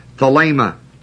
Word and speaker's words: thelema: The word will is the word thelema, T-H-E-L, long E-M-A.